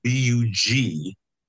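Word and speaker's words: Bugialli: And Bugiali is Bugiali. I-A-L-L-I, Giuliano Bugiali.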